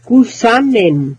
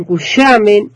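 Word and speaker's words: Cushamen: Yo, para mí, es Cusamen, como se escribe. Muchos le dicen cuyamen, otros dicen o sea, yo, para mí, es cusamen.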